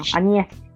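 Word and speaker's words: Áñez: Añez es el apellido, es difícil, por eso me trabé un poquito, pero Añez.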